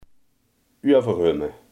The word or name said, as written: overhemd